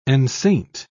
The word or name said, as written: enceinte